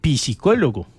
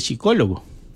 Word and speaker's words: psicólogo: A mí me da pena corregirlo, pero no se dice psicólogo, se dice psicólogo, porque la P no se pronuncia.